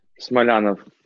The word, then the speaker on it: Smolyanov: Smolyanov. So you said Smolyanov is the Russian way of saying it, and Smolyanov is the English version of it.